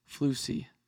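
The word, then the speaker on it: floozy: Floosy. – Floosy. – Floosy. – Rhymes with Lucy.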